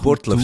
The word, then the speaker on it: Burgdorf: kommen wir nach Burgdorf. – In die Burtlöf, sagt man. Burtlöf.